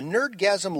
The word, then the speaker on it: Nerdgasm: The Nerdgasm Lulz cast, this is spelled N3RDG4SM, but pronounced Nerdgasm, Lulz cast, L-U-L-Z-C-A-S-T, good luck finding them, is four guys, LJ, Joe Keg, Jerry H.